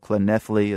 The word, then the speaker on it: Llanelli: It's called Llanelli. It's a Welsh pronunciation, which is never easy, but I guess I got as close as I can get.